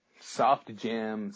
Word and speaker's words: Sufjan: What's making me happy this week is the music of Sufjan Stevens. I've heard of him, but I never can remember how to say his name correctly. Sufjan Stevens? Sufjan? Sufjan? – Soft Jam Stevens.